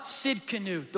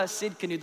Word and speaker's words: Tsidkenu: Jehovah Tsidkenu, the Lord is my righteousness. The T is silent. Jehovah Tsidkenu, the Lord is my righteousness.